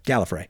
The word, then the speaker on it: Gallifrey: Gallifrey.